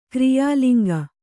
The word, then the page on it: ಕ್ರಿಯಾಲಿಂಗ: kriyā liŋga